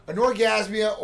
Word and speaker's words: anorgasmia: Anorgasmia, that's how you say it.